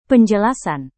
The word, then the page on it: penjelasan: indonesian